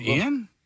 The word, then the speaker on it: Ioan: I-O-A-N is how you spell his first name. – All right, hold on. Ian? – No, I think it's Ion.